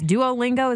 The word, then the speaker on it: Duolingo: Duolingo is that how you say it? Mm-hmm.